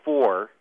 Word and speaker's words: Fur: One is called the Four. It's spelled F-U-R. In fact, Darfur means land of the Four. The other group is Zagawa, Z-A-G-H-A-W-I-A, and the third group is Mazalit.